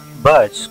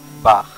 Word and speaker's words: Bach: Y una cosa antes de pasar con Mozart, es que, como se dieron cuenta, no se pronuncia Bach como bien escrito en su nombre, sino que su nombre se pronuncia Bach.